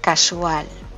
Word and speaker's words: casual: Vamos a empezar por casual. Este es el significado más antiguo de la palabra. Y quiere decir que algo sucede ocasionalmente, casualmente, por casualidad, y se pronuncia con acento en la sílaba al.